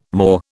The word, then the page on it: Moor: British English